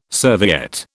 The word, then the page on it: serviette: British English